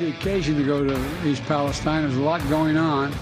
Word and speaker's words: East Palestine: Maybe somebody should tell him that it's pronounced East Palestine so that he can pronounce the name of the town correctly that suffered a very traumatic hazmat incident with the train derailing there.